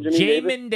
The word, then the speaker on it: Jamin: Jamin Davis.